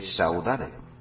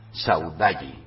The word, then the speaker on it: saudade: no se lee Saudade que es un vocablo tomado del portugués y que se pronuncia saudalli.